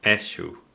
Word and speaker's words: eixo: Eshu. – Yeah, eshu. So it's spelt E-I-X-O, eshu.